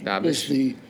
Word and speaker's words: Dabisch: Dabisch is a German name. Dabisch. – Dabisch, yes. – Dabisch is the true pronunciation.